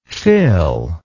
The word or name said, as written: fil